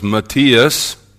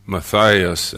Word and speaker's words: Matthias: I got to seminary before I learned that that man who was elected to be the twelfth apostle was named Matthias and not Matthias.